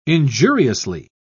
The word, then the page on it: injuriously: British English